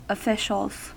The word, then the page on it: officials: American English